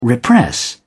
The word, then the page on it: repress: British English